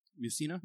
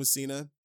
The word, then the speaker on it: Mussina: Game one, Mike Mussina. Am I saying that right? Mussina, yeah, yeah. You're Mike good. Mussina.